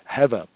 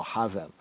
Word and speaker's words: Hevel: Hevel in the Hebrew, Hevel. Some modern Yehudi and Jews would say Hevel, right? Or Havel, Havel. They'll pronounce it as Havel. So the distinction between Abel and the word for vanity is a nuance of pointing. For Abel, Habel, Habel, or Havel, and for vanity, Hevel, or Hebel, Hebel.